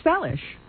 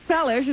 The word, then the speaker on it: Seles: I do, because it was originally pronounced Seles, and then she changed it to Seles.